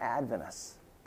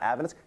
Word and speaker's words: Adventist: And you can always tell when someone's not Adventist because they'll say Adventist, not Adventist. They'll put the emphasis on the wrong syllable.